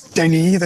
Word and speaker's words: Denis: I mean, officially, it's Denis. That's the French pronunciation.